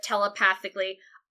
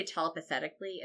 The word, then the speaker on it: telepathically: Homura telepathically, I used to say telepathetically as a child for years before I said this right.